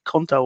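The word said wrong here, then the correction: Couto: was it? Sorry, Cuto.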